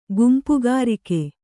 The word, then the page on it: ಗುಂಪುಗಾರಿಕೆ: gumpugārike